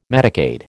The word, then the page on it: Medicaid: American English